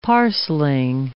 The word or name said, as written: parcelling